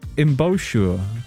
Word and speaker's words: embouchure: I don't think I've said it out loud before, though. Embouchure. E-M-B-O-U-C-H-U-R-E.